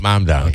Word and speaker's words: Mamdani: But, you know, in the 21st Congressional District, a Democrat it looks more like a Gendebean than Mondami. Mamdani. It's Mon, isn't it? Mondami? – M-A-M. – M-A-M. Mamdani. – Mamdani.